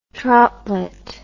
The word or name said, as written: troutlet